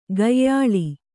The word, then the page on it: ಕೊಯ್ಯಾಳ್: kannada